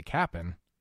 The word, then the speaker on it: Cap'n: You see, the Cap'n, because that's how you say it, might not actually be a Cap'n after all.